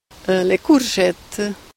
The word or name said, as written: Courgette